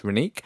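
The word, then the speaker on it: Rennique: So there's a local artist as well, Renick, R-E, well, actually, it's actually pronounced Renick. It's R-E-N-N-I-Q-U-E.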